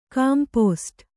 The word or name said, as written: ಕಾಂಪೋಸ್ಟ್